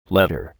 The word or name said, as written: letter